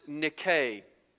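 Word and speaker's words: nike (νίκη): There's a mythological figure who is this warrior goddess, and she was victorious, and she was called Nike, and that's the way you would pronounce this word.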